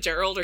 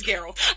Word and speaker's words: Geralt: The only person play Geralt. – Is it Gerald or Geralt? – It's Geralt.